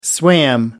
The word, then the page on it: swam: American English